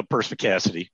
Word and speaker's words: perspicacity: people, you do have perspex glossary. Is that right? Did I say that right? – I have what? – You have perspex glossary? Is that what it is? Perspex glossary. Well, perspicacity.